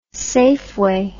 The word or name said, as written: safeway